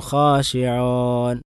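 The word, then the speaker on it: ഖാശിഊൻ (خاشعون): ഇപ്പം ഈ മുഅ്മിനൂൻ ഖാശിഊൻ എന്നുള്ള ഉച്ചാരണം അല്ല ശരിക്കും വാവിൻ്റെ ഉച്ചാരണം. വാവിൻ്റെ വാവായി തന്നെ പറയണം അത്. ഖദ് അഫ്ലഹൽ മുഅ്മിനൂൻ മുഅ്മിനൂൻ ഖാശിഊൻ ഖാശിഊൻ എന്നല്ല ഖാശിഊൻ.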